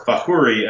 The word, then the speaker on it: Fakhouri: but the Arabic spelling of our name is F-A-K-H-O-U-R-I. We have hundreds of relatives who spell their name that way. And Fakhuri, or Fahuri, as my family pronounces it, translates to Potter.